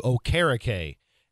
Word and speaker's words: Okereke: I was going to get into this later in the week, but since you brought it up, he's changed his pronunciation for his last name to Okereke, and he's honoring his Nigerian descent.